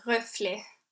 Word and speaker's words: Reflet: So the name of the piece is Reflet.